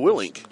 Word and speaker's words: Willink: Willink. I can never say his name. Right. – It's Willink. I butchered that.